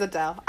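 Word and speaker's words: Adèle: Adele. It is Adele. I was right. – Is it spelled the same way as it? – A-D-E with a accent mark, L-E.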